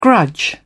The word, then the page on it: grudge: British English